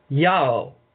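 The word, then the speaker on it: Iao: And in two verses, verse 3:12 of chapters of the book of Leviticus, and 4:17, his name is pronounced or spelled Io. It is Iota Alpha Omega. Okay. Which would be Yao in our tongue.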